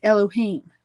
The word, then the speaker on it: Elohim: I want you to know that this is the word that's pronounced in the modern Israeli Hebrew is Elohim.